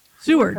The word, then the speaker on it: Seward: Seward. How do you say that? Seward.